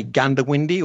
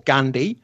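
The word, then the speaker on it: Goondiwindi: And if you want to look that up, it's actually Goondawindi, but we locals say Gundawindi or Gundy.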